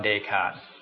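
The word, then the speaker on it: Descartes: อ่านว่าเดคาร์ตดูมันว่าเดสคาร์เทสแต่ว่าเขาอ่านว่าเดคาร์ต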